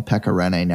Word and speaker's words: Pekka Rinne: It's nice that you call him Pekka Rinne now and not Pekka Rinne anymore.